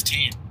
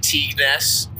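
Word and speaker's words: Tignes: Tees? – Yep. – I think it's Teen. – I was pronouncing it Teagness for the first couple days.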